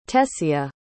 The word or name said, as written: tessia